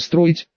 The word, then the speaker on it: строить: Точнее, уже строить, с ударением на букву «и».